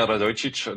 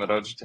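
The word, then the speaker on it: Radojičić: Ifølge Kosovos regering, så er lederen af den øh, cirka 30 mand store gruppe en fyr, der hedder Milan Radojic som måske øh, udtales noget lignende. Han er en kosovo-serbisk politiker, som øh, støtter og er støttet af Serbiens præsident Aleksandar Vucic. Hvad fortæller det om, øh, om situationen? – Ja, altså ham her, Radojcic, det var faktisk en fin udtale.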